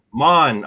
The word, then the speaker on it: Mahn: Anyway, today's guest is Arthur Mahn, and I pronounced that right, didn't I, Arthur? – It's Arthur Mahn. – Mahn. Oh, there we go. See, I pronounced it wrong.